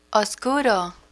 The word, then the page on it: oscuro: Spanish